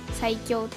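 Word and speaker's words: 彩響: まあまあまあまあまあ、そんなこんなで、今年のねテーマが彩りに響くって書いて、はい、最強って読むんですよ。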